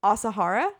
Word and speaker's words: Asahara: Asahara. Mm-hmm. Is that how you pronounce it? Okay, yeah. Great. So Asahara,